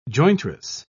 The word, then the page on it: jointress: British English